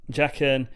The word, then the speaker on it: Jaqen: is it Jaqen? – Yeah. – Jaqen, the waif wanted to kill Arya.